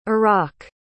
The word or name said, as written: iraq